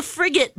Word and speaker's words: frigate: It's actually, I'm sorry, it's the frigate bird. Am I saying it right? Frigate. – I don't know. – It's the frigate bird. – Did I say frigid? – I said frigid.